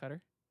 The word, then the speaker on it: Qatar: Q-A-T-A-R in the Middle East. – Qatar? – Q- cutter. – Do you say a cutter? – That's how it is pronounced. – Oh, no. Oh, no. I've been saying it wrong for so long. That's on me. – I only say it that way because I know I've heard it officially pronounced that way.